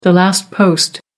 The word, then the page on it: last post: American English